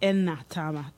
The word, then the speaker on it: Enatama: and it's pronounced Enatama, because, you know, because of the phonetics of the language that it comes from.